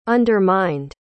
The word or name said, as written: undermined